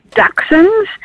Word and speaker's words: Dachshunds: Is it Dachshunds or Dachshounds? How do we say it? – Well, that's a very good question. The proper terminology is Dachshunds.